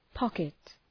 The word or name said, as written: pocket